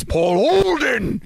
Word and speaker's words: Paul Olden: You're saying it wrong. It's Paul Olden.